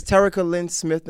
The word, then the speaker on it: Terrica Lynn Smith: I got uh, Miss Terrica Lynn Smith in the building.